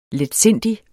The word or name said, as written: letsindig